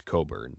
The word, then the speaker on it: Cockburn: The pronunciation of his name, you know, it's spelled C-O-C-K-B-U-R-N. You would think Cockburn by English pronunciation. So he is Jamaican, originally Jamaican, and then moved to New York, where, you know, obviously played high school and travel and all of that kind of stuff. But from the Jamaican pronunciation is Coburn.